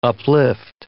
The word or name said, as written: uplift